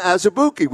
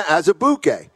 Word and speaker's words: Azubuike: I like the work you do, but you're pronouncing Kalena's name wrong. – Oh, wow. – And I'm like, what do you mean? It's Azubuike. He's like, no, it's Azubuike. Or Azubuike. And I said, really? He's like, yeah, that's the way it is in Nigerian.